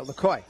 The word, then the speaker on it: Lacoy: I think I've been putting an A sound at the end of Lacoy's name. So Lacoy, yes, thank you.